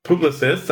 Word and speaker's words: Publicis: Today, our special guest is Jason Goldberg, Chief Commerce Strategy Officer, Publicist. Hopefully I pronounced that correctly after you gave me a lesson here. And host of the Jason and Scott e-commerce podcast. So welcome, Jason. – Hey, thrilled to be here. Thanks for having me. And congratulations on the pompous French pronunciation. You nailed it.